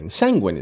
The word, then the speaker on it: sanguine: Sanguine is the pronunciation. I don't know where my head is. I was not sanguine.